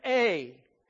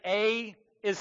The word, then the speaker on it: Ai: So it really would be A, not AI, but we're going to call it AI because that's what we have in our Bibles.